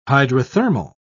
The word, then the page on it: hydrothermal: British English